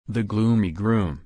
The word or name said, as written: the gloomy groom